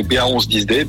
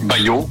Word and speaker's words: BA111OD: Donc vous pouvez prononcer ba 10 d BA111OD, Bayo, votre Génial.